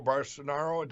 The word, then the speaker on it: Bolsonaro: Eduardo Bolsonaro.